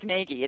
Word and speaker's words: Nagy: It's spelled like Nagy. It's N-A-G-Y. So if anybody wants to, you know, find me, it's easier to go by the phonetic spelling.